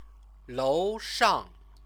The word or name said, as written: loushang